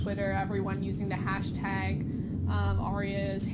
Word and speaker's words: ARIAs: Is that how people pronounce it, the Arias? Because that's how I've been saying it. It's an award... You think so? Okay, good. it's an award show in Australia.